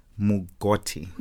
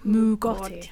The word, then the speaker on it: mugoti: A Mugoti. Have I said that right? – Mugoti. – Mugoti. – Yes.